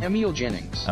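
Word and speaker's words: Emil Jannings: Emil Jennings.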